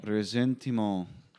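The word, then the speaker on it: ressentiment: Mock Shaler wrote a book called, how do you pronounce that in the French? – I don't even know what I'm looking at here. – Top word. – Resentiment. – Okay, resentiment.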